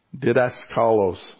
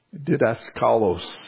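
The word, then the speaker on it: Didaskalos (διδάσκαλος): Didaskalos. Sorry, I mispronounced. Didaskalos,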